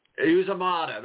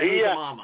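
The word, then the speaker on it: Uzomah: Uzomata, that's right. Yeah. I always want to say Uzomama.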